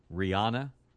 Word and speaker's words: Rihanna: Number two would be Rihanna.